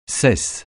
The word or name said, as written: cesse